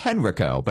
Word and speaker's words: Henrico: H-E-N-R-I-C-O. It's Henrico, but it's pronounced Henrico.